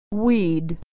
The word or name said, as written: we'd